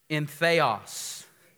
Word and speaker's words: entheos: Everybody say entheos. Y'all didn't say it right. I'll say it. Entheos.